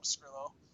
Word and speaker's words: Pescrillo: All right, you can find me at Paul Pescrillo.